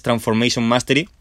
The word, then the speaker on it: Transformation Mastery: O sea, lo he dicho cómo se escribe, pero es Transformation Mastery, ¿vale?